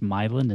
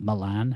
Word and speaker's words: Milan: Apparently, it's pronounced Milan and not Milan. How it looks, the Milan mm-hmm.